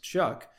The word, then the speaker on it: Shough: Tyler Shough, whose actual name is spelled S-H-O-U-G-H, but pronounced Shuck, played for three different college football teams over a six-year period.